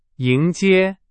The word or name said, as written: 迎接